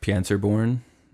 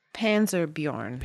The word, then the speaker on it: Panserbjørn: They ask what he's doing there as a Panzerborn. Am I saying that right? – Panserbjørn.